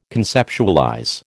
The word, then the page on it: conceptualize: American English